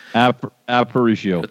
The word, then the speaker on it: Aparicio: Aparicio.